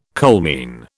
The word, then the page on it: coalmine: British English